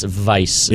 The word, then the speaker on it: Weiss: It's Vice. That's Vice. The W-E-I German Vi.